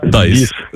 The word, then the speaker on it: Dies: От пишеться D-I-E-S. Dice.